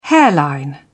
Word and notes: hairline: British English

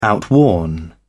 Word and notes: outworn: British English

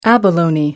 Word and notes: abalone: American English

